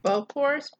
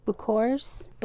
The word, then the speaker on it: bokors: Bokors, B-O-K-O-R-S. – Bokors? – Bokors.